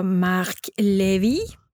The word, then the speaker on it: Marc Levy: Oh, oh och det är en bok av Marc Levy.